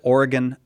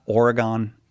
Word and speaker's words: Oregon: Did you know, Mark, that you pronounce it Oregon, not Oregon? – I knew that.